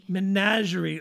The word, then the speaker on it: menagerie: And so there was a thing called the Star Troop Menagerie and Circus. I think that's how you pronounce that. – Menagerie. – Ooh, menagerie.